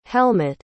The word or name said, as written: helmutt